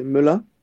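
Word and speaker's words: Melun: Eh, melón, ¿Melún? – ¿cómo se dice? Sí, te este digo, se llama este Melan.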